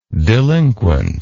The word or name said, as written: delinquently